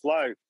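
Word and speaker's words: Flå: it's spelled FLA, but the A is like you know, or O. – Yep. – And so it's pronounced Flo.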